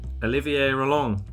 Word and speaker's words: Olivier Roland: I'm with Olivier Roland.